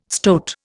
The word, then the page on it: stoat: Indian